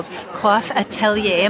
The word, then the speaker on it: QOF Atelier: Okay, so I have come across this booth called QOF Atelier.